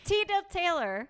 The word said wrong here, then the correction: teetotaler: No, it's just it's a teetotaler.